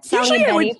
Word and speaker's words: Saglimbeni: Saglimbeni for the record, but it's just too hard to say.